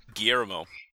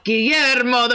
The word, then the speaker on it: Guillermo: So how do we pronounce it? – Guillermo. – Guillermo del Toro.